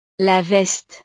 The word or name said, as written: la veste